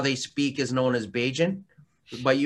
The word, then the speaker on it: Bajan: How do you pronounce the name of the sauce? – Beijin Tiger. – Beijin Tiger. – Here's the story behind it. Beijin is the term you would use for someone from Barbados.